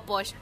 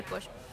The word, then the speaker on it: pox: Ojalá, si ustedes pueden venir, prueben todo esto, el sotol, bacanora, ron y pox. Bueno, o posh. Se escribe pox, pero se dice posh.